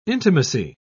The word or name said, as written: intimacy